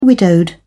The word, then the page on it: widowed: British English